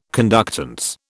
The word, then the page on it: conductance: British English